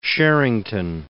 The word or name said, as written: Sherrington